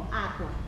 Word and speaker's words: aqua: Porque, por ejemplo, yo les digo, aqua. La sílaba acentuada, ¿cuál es? – A. – Y resulta que en qua tengo dos. Naturalmente me puede salir aqua. ¿Ven? Y no es.